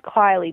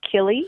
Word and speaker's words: Kilee: Mine is spelled S-E-A-N, and some people refer to me as Sean. – My name's Kylie, but spelled K-I-L-E-E, so everyone says Killy.